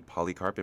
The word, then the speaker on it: Polycarpe: Well, we have Jordi Polycarpe.